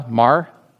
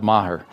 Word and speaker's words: Maher: In 2008, Bill Maher, did I say that right, Kyle? Maher, spelled Maher, Maher.